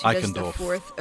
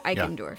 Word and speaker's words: Eichendorff: Eichendorff. – Okay, Eichendorff.